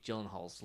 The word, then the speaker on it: Gyllenhaal: The best one is Jake Gyllenhaal's last name is not pronounced that way. – Oh, really? – Yeah. It's pronounced, because I think it's like Swedish or Danish. – Yeah. – So it's got that very, Gyllenhaal. That's how you're supposed to pronounce it.